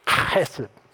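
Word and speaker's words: chesed (חסד): It's the word chesed. And if you don't spit on somebody around you, you haven't said it right.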